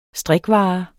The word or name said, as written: strikvare